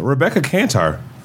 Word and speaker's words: Rebecca Kantar: Rebecca Kantar.